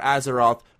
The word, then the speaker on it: Azeroth: The latest World of Warcraft expansion, Battle for, and I apologize if I mispronounce this, if you know how to pronounce it, please correct me, Battle for Azeroth? – Azeroth. – I'm going to go with Azeroth. – That's pretty close, yep.